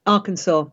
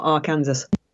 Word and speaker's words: Arkansas: Arkansas, not Arkansas.